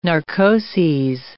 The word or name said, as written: narcoses